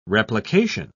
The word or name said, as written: replication